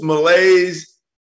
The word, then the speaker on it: malaise: Jimbo's malaise at A&M. Did I say that right, Pat? Did I say that right? – You did.